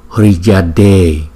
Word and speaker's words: Hriyade: Thì cách chúng ta đọc câu chú này là như thế này: Om Om Om Hriyade Hriyade Hriyade. Chúng ta thấy chữ D và chữ H ghép lại với nhau thì chúng ta đọc là D D D.